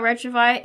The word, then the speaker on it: Retrouvaille: And to give a little background about Retrouvaille, it's Retrouvaille, that's how it's pronounced, is French.